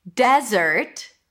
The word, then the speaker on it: desert: Desert stresses on the first syllable and dessert. The first one is desert as let's go to the desert, desert. The second one is dessert.